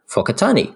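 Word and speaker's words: Whakatāne: I'm no expert in Te Reo Māori, but generally the F sound is used with words starting with WH. For example, Whakatani.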